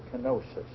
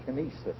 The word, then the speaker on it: kenosis: We pronounce it kenosis, but I think the Greeks pronounce it kenesis, and it means empty.